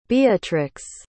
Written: beatrix

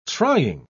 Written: trying

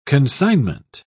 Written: consignment

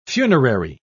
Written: funerary

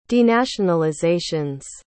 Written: denationalizations

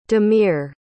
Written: damir

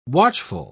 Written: watchful

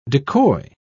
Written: decoy

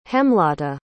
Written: hemlata

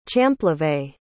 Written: champlevé

